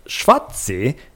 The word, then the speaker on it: Schwarzee: But pronounced Schwatzi, yes, like the game Yahtzee, our Schwarzbier, German for black beer, is a delicious lager that is incredibly light and smooth, with a nice roasted malt character.